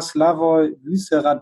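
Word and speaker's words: Slavoj Vyšehrad: Unser letzter Stand war, dass du den Prager Zweitligisten, muss ich kurz gucken, ob ich das richtig ausspreche, FK Slavoj Wyssera trainierst. Aktuell ist... Ja, falsch? – Ja, der Verein ist richtig. Also, falsch ausgesprochen. – Du hast alles perfekt gesagt.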